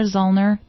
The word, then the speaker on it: Zoellner: Tom's last name is spelled Z-O-E. L-L-N-E-R, Zollner, Tom Zollner.